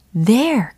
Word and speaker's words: there: here and there. 그렇죠. 이 there 이라는 단어입니다. there, there. 발음 연습해 보시면 좋겠죠? there, there. 알던 단어도 다시 짚고 넘어가야죠. there, there. 돼요 아니고 there, the, the, there 이렇게 예, 연습하시면 되겠습니다.